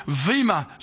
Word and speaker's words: Bema: This is this judgment seat of Christ, this Vema seat, spelled B-E-M-A, pronounced with a V, this Vema seat of Christ is a throne of rewards.